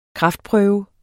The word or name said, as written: kraftprøve